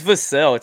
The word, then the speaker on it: Vassell: Devin Vassell. I sure hope I'm saying his name right. – It's Vassell. It's like Cassell. – Thank you. Okay. Devin Vassell, like Sam Cassell.